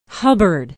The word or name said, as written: hubbard